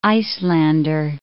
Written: Icelander

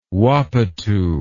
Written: wapatoo

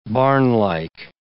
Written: barnlike

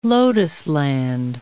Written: lotusland

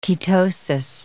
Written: ketosis

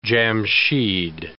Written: Jamshyd

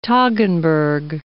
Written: Toggenburg

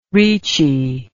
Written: reechy

